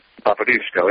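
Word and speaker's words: Popiełuszko: Father Jerzy Popielusko. Am I saying it correctly? – Popielusko, yep.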